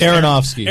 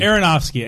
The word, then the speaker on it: Aronofsky: Aronofsky. – Aronofsky. Androfsky or something.